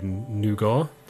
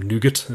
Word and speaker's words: Nougat: These phones no doubt will be running Android Nougat, or Nougat, as the Americans say.